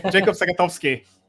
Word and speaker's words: Jakob Sagatowski: Jakob Sagatowski.